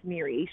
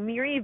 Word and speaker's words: Moira: Her name is spelled Moira, M-O-I-R-A, but it's pronounced Miri. She always says theory, but with an M. So Miri Villiard, just before the pandemic hit, said, you know what, I'm going to go it alone.